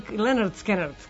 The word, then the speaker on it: Lynyrd Skynyrd: kažu da je ovo pesma treća najpopularnija pesma grupe Lynyrd Skynyrd ili Lynyrd Skynyrd, kako oni kažu,